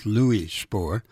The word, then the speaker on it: Spohr: That is the first name he was called on birth, at birth, Louis Spohr. But he was born in Germany and was baptized Ludwig Spohr. So you won't be penalized by either pronunciation.